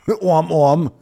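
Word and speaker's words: Aumm Aumm: Om Om. It's spelled A-U-M-M, Om Om.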